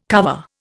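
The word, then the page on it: cuvée: Indian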